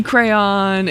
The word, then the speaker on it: crayon: I think you're missing a syllable if you say crayon. Like, you're, crayon. – That's, crayon is one syllable. Crayon has two syllables written out in the...